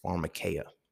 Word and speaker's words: pharmakeia: So when we look at the root word of pharmacy, pharmaceuticals, all of those, it's pharmakeia, right? Pharmakeia, it is spelled P-H-A-R-M-A-K-E-I-A, I believe, but it's pharmakeia, right?